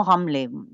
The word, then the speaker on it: Ramla: la ville de Ramla, qu'on prononce plus couramment Ramleh, pour ceux qui connaissent.